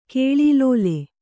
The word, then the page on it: ಕಾಲಲೀಲೆ: kālalīle